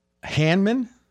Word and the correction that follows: Hardman: Is that how you say his name? – Hardman. – Oh, I looked. That's an R.